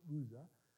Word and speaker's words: Uzzah: So what happens is, as they leave that house of Abinadab, and there's a guy named Uzzah, and it's actually Uzzah, and I put the Hebrew there because, you know, I need that to know exactly how it's supposed to be pronounced. It's Uzzah.